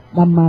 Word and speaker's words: Dhamma: Thì tương tự, Dhamma là Pháp, thì họ đọc là Dham, Dham, bỏ cái phần gọi là âm cam nô sao đi.